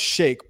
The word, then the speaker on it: Sheik: It's pronounced shake, but whatever.